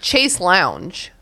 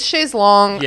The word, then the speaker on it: chaise longue: Today I was talking about the word chaise longue, which my dumb American brain is like chase lounge. But it's like, it's chaise longue.